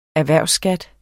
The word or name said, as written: erhvervsskat